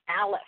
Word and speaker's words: Aleph: of A, this is Aleph. A-L-E-P-H mm-hmm. is how we spell it, but then it is technically A-L-P because they drop off the vowels, and it means bull or ox.